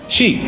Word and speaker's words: Xi: Xi.